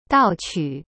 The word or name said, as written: 盗取